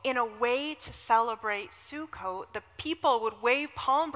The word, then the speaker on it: Sukkot: So this festival is called Sukkot.